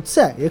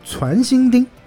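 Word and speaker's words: 攒心钉: 提手旁加一个赞叹的“ 赞”，两个读音，既可以读“ 在”，也可以读“ 传”。读“ 传” 的时候呢，有传射之意啊，就是集中起来射向你啊，这个叫传射。在这边应该读作叫传心钉啊，是这么一个东西。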